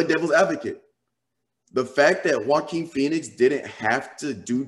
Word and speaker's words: Joaquin: Is it Joaquin or Joaquin? Yeah, it's Joaquin Phoenix.